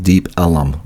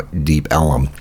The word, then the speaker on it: Deep Ellum: they kind of mispronounced for many years, Elm, E-L-M, with Southern accent, to the point so much that they've just kind of added another L-U-M, so it's now Deep Ellum with this area.